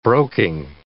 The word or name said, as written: broking